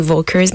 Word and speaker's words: Völkers: Euh, puisque c'est allemand, c'est Engel. La majorité des gens disent Engel and Volkers, mais il faut prononcer le V comme un F.